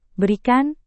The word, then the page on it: berikan: Indonesian